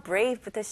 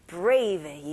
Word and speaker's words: brave: 네, 일단 B 발음은 입술이 붙어야죠. 그래서 B, B. 그 다음에 R 발음. R에는 힘을 주셔야 돼요. Brave. 네, V 발음으로 마무리하는데 입술이 붙으면 안 되죠.